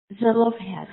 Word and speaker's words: Zelophehad: Zelophehad, yes, yes.